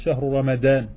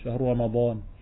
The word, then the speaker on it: Shahru Ramadan: C'est pas grave s'ils disent ça pour, et euh, pour discuter entre eux, mais lorsqu'ils disent le Coran, ils ne devront pas dire Shahru Ramadan. D'accord Ils devront prononcer le dad. Shahru Ramadan.